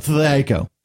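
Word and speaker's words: THAC0: It's actually it's pronounced two hit armor class zero. – Well, that's what it stands for. But no, that's the way it's pronounced as well. – Oh, okay. – You just have to say it really fast, and then it comes out Thaco.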